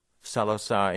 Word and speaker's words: Saloth Sar: The man's original name, I hope I'm pronouncing this correctly, Saloth Sar? – Saloth Sar, indeed, and a nom de guerre, but one of many.